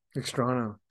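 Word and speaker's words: Extraño: Extrano. See, sometimes I say it right and then I doubt I myself. You, no, I think you got it, man.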